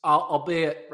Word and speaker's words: albeit: Is it albeit or is albite? How do you say that word? Albeit? – No, albeit? – You did it right. You did it right the first time.